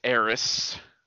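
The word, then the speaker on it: Aerith: to, I par- I've met Eris. That's how you pronounce it.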